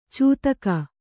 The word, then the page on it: ಚೂತಕ: cūtaka